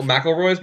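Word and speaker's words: McElroy: You don't even pronounce their name right. – Oh, McElroys? McElroys. Yeah. Sorry. McElroys, whatever.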